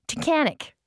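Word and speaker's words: Tunkhannock: He says it rhymes with mechanic, and then it looks like... Tunkanuk, so it must be Tunkanic. – Hold on, it rhymes with mechanic. Tunkanic. Tunkanic. I think that's how you say it.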